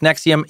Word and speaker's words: NXIVM: Spelled N-X-I-V-M, but pronounced Nexium. Yep, like the brand name of the drug that treats acid reflux.